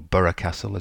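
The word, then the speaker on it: Burgh Castle: They include Brancaster, Burr Castle, or Borough Castle as it's pronounced,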